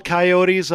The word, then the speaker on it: coyote: Do you all hunt liberal coyotes up in Wyoming? – Here's the, you know, and thank you for saying it right. As Warner Brothers taught us, it's coyote, as in Wiley.